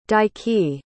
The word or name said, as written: daiki